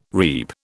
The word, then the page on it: reap: British English